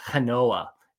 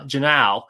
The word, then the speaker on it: Genao: And I think my first pick, so this is sort of RoboScout intriguing names, is Cleveland Guardians shortstop at low A, Angel Genoa, G-E-N-A-O. I'm going to pronounce it Genoa. He is just it's Genao. – Genao. I prefer Genoa. It's Angel Genao.